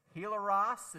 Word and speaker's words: hilaros: Hilaros is how that's pronounced.